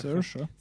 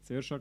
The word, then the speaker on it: Saoirse: Ähm, die Hauptdarstellerin, sage ich nicht, den Vornamen nicht aussprechen kann aussprechen. Saoirse. – Saoirse, genau, Ronan.